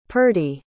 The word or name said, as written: purdy